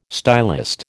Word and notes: stylist: American English

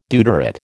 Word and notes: deuterate: American English

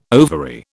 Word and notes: ovary: British English

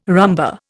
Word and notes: rumba: Indian

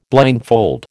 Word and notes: blindfold: American English

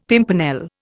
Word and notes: pimpernel: Indian

